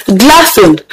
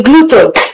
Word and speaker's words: glutton: It's actually not correct. So it's incorrect to say gluten. No, the word is pronounced glutton.